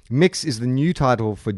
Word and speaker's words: Mx: MX, pronounced or to sound like Mix or Mux.